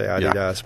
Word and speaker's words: Adidas: You have to say that right. It's Adidas. – Well, if you're in Europe, you'd say Adidas, but we say Adidas.